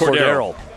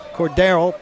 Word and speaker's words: Cordarrelle: Cordarrelle Patterson, who I don't know if I pronounce his first name correctly because it's so ridiculous. – Cordarrelle. – Thank you. Cordarrelle Patterson, over 10.5 carries for the Falcons.